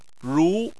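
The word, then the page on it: ru: tone 2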